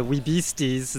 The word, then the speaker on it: Wee-Beasties: We're out here with the band the Wee-Beasties.